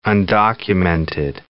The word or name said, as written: undocumented